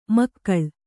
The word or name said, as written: ಮೇಖಳೆ